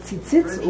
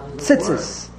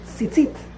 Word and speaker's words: tzitzit: A lot of the B'nai Torah, a lot of the Ashkenazic people, the B'nai Torah, they pronounce a chirik like a kama, like a shva. – Tzitzis. – Tzitzis, right? Tzitzis? That's really wrong.